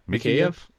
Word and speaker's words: Mikheyev: How do you pronounce that? – Mikheyev.